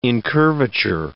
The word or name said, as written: incurvature